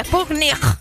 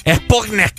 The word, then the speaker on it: Sputnik: La Es que pronunciámelo con la K, como se debe. Sputnik. – Sputnik.